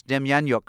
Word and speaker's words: Demjanjuk: Mr. Douglas, first question, did I pronounce Demjanjuk correctly? – Very impressively, I would say.